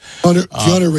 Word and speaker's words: Keanu: I think of Canal the Matrix guy, Canal Reeves, right? Am I saying his name right? Canal Reeves? – Keanu Reeves. – Keanu. That's the way you say Keanu.